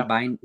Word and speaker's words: Bieniemy: And, you know, I don't know if Sam Howell is the guy, but I do know that Eric Bieniemy, is that how you say it? Bieniemy? – Yeah. – Bieniemy. And it's an enemy.